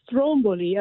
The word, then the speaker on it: Stromboli: I've been there, and they didn't pronounce it correctly in the Disney movie. And of course, I grew up with that. So when I went to Stromboli, I was saying it wrong the whole time. But the accent is on the Strom.